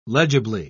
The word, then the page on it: legibly: British English